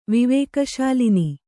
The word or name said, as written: ವಿವೇಕಶಾಲಿನಿ